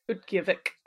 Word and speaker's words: Utqiagvik: Name Utqiagvik or Utqiagvik? – It's Utqiagvik. – Utqiagvik, yeah.